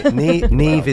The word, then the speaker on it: Niamh: Neve is, of course, yes, I was mispronouncing it before we came on air. It's the Gaelic sort of pronunciation, but N-I-A-M-H is how the name is actually spelled.